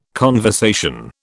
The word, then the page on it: conversation: British English